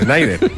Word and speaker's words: Schneider: Se te pasa por no confiar en tu pronunciación, que la habías dicho bien, Schneider.